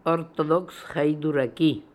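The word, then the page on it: Orthodoxhydooraki: Russian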